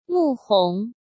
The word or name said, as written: Mu Hong